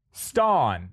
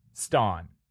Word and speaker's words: Stonn: So don't get tripped up. Stawn. If you need a spelling, I can spell it for you. Stawn. – Please do. – S-T-O-N-N. Stawn.